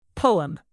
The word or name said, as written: poem